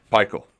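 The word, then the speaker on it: Pikiell: Say his name again, Roush. – I don't know why I said Pekile. Tough as nails, Pekile. – Pikel. – I don't know.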